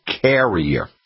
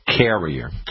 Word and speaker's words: Kerria: But when the forsythia gets done blooming, the next plant that flowers is called carrier. And it's spelled K-E-R-R-I-A, carrier.